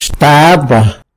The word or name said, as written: xtaba